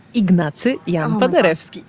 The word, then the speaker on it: Ignacy Jan Paderewski: In 1893, I believe, and a few times before and after that, the pianist, and forgive me if I'm saying it wrong, is it Jan Paderewski? – Ignacy Jan Paderewski. – Ignacy Jan Paderewski. Okay, I butchered it, I'm sorry.